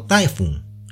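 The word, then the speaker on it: taifu (台風): Como estamos en Japón, este fenómeno natural se llama tifón, palabra que proviene del chino taifun y que en japonés se pronuncia taifu.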